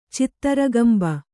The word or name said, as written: ಚಿತ್ತರಗಂಬ